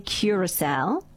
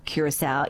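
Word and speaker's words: Curaçao: The country is definitely Curacao. However, the drink, blue Curacao, is sometimes pronounced as Curacao.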